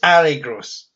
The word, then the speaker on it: Ari Gross: So Ari Gross is in this movie, which is good because it means I can apologise to Jen for mispronouncing his name last time and making her do it too. So I'm sorry, Jen. It's Ari Gross.